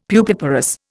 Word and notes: pupiparous: Indian

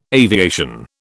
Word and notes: aviation: British English

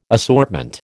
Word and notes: assortment: American English

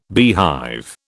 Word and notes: beehive: British English

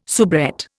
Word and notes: soubrette: Indian

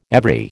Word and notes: every: American English

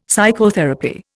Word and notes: psychotherapy: Indian

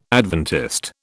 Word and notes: Adventist: British English

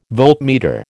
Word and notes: voltmeter: American English

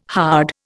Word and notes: hard: Indian

